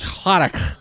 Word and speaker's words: Cladach: We're at the Cladage Cottage. – Cladach. – Am I saying that right? – I say Cladach. Any opportunity to like hawk up a loogie, go for it.